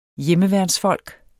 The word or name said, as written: hjemmeværnsfolk